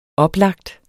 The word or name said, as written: oplagt